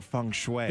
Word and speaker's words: feng shui: Oh, I'll celebrate my small victory earlier in the show saying feng shui. – That is correct.